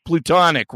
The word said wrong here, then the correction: platonic: right? And I'm like, what do you mean joking? Well, obviously you would joke because that's not... How do you say plutonic, Kathy? – Platonic. – Oh, Jesus fucking kill yourself, Christ. Are you kidding me? – It's not Pluto.